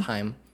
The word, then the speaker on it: Haim: I said Haim before, it's Haim.